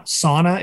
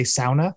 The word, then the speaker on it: sauna: So like that was the place I learned that uh, sa- sauna is not the proper way to say that. You're supposed to say sauna.